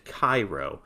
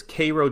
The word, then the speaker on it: Cairo: People who don't know South Georgia usually pronounce Cairo like the one in Egypt, Cairo, but this one is pronounced K A Y R O W. He says Cairo, Georgia is famous for making syrup, although syrup apparently hasn't been made on a large scale since the 1990s.